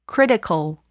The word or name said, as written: critical